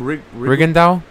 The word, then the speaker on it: Rigondeaux: Rigondeaux.